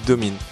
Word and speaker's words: Domine: Eh bah je suis désolé, mais à l'anglaise, le i dans ce mot-là se prononce pas comme ça. Voilà. Là ça se dit Domine.